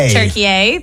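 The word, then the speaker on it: Türkiye: Turkey. – That's how you say it. That's how they pronounce Turkey now. I did not know this.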